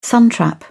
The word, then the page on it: suntrap: British English